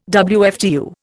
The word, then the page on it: WFTU: Indian